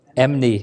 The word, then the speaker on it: enmity: Do you not know that friendship with the Lord is, how do you say that? Enmity? Sounds right. Enmity, thank you.